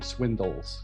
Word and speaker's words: Swindles: Where I'm from in Northern England, we have a very specific accent, and we call my last name Swindles with a dill at the end.